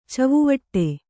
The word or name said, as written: ಛವಿವಡೆ